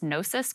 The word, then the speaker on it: Gnosis: G-N-O-S-I-S. Gnosis coins? – Yeah, that's Gnosis.